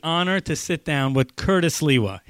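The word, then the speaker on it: Curtis Sliwa: And Curtis Liwa, yeah, I pronounced it right.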